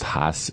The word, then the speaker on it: Haas: I guess it's pronounced Haas, H-A-A-S.